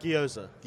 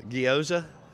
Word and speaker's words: gyoza: Did I say that right? – Gyoza. – Gyoza. – It's like Asian dumplings. – I just pronounce it like if you're from Baden. – Like you coached Charles Cross in high school. – Gyoza? – Yeah, nailed it.